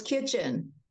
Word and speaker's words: Kitchen: Am I saying it right, Miss Addie? – No, it's Kitchen.